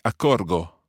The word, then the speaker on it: accorgo: And if you look to the I form, it would be accorgo. – Accorgo, sì. – So I've got that hard G sound there, and that's where we take the subjunctive form from.